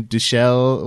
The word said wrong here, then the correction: Dushku: What's it? Eliza Dushku. Dushku. Yeah.